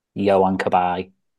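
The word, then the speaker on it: Johan Cabaye: Johan Kabay.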